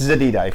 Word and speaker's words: Zededa: Um, ultimately, uh, you know, I think what matters most is not only pronunciation, uh, but you know, it's Zededa if you really want to, to say the right way.